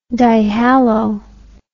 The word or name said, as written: dihalo